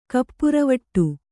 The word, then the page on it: ಕಪ್ಪುರವಟ್ಟು: kannada